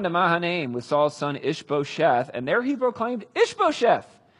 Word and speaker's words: Ish-bosheth: Ish-bosheth is how you say it.